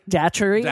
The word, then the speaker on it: Datchery: There's a character in the show named Dick Dacquery. – Yeah. – No, I just said it wrong. Dick Datchery.